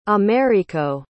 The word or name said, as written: americo